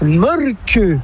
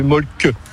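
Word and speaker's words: Mölkky: Ça s'écrit Molki, mais ça se prononce Molke. – Le Molki. – Plutôt un E qu'un E, mais pas un U. Donc ça fait Molke.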